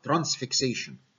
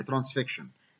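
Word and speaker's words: transfixion: فهعمل لها حاجه اسمها ترانسفيكشن، واحيانا تنطق خطا ترانسفيكسيشن، هي الصح ترانسفيكشن، اعتبر ده الثايرويد اسمس.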